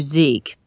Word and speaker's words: Zeke: We're calling you Zeke. It rhymes with freak, which makes it easy to remember, especially because we're a little freaked out right now.